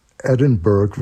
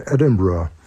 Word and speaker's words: Edinburgh: Und der letzte Punkt ist, Edinburgh wird nicht so ausgesprochen, sondern Edinburgh.